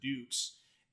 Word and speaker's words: Dux: It's about a man named Dukes, and they D-U-X, but they pronounce it Dukes.